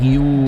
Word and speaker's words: Guiu: No, ese, este es Guiu, Guiu catalán y el otro un Nahashi. – Vale. – Este Nahashi, es Guiu, se escribe.